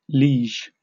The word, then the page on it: liege: British English